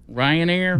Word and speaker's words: Ryanair: A Riot airplane? – How do you pronounce it? Ryanair? What is that from? Rio de Janeiro. A man was nearly sucked head first out of a cabin window in midair on a Ryanair plane.